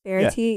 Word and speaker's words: Verity: It was cool that Verity... Am I saying her name right? – Verity, yeah.